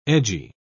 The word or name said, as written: edgy